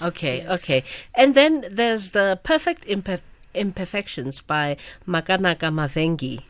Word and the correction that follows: Mavengere: No, I didn't say that correctly either. – Yes. So Perfect Imperfections is by Makanaka Mavengere.